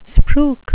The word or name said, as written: spruik